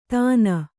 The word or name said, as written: ತಾನ